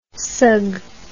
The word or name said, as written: sug